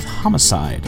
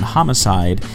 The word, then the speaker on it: Hamacide: And if you're interested in finding information, Hamacide is a play on the main guy's name, Yusuke Hama. So it's spelled H-A-M-A side.